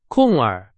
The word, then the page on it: 空儿: Chinese